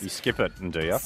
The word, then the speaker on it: Nduja: And it's spelt N-D-U-J-A. You pronounce it as Nduja, but everyone calls it Nduja.